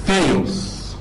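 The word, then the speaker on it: ثيوس (θεός): لا، دي تتقري ثيوس. لو انا حطيت نبره هنا المفروض تتقري ثيوس.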